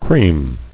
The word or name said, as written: Creaming